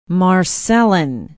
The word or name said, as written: marcellon